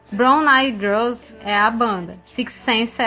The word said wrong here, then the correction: Sixth Sense: é a música. – e eu de pronúncia difícil falar Sixth Sense. Sixth Sense.